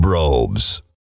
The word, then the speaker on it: Brobes: The Golden Brobes. Rhymes with globes. You're right. It does.